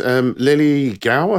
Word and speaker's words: Lily Gao: Lily Gao, I think is the way you pronounce it.